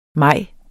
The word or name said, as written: maj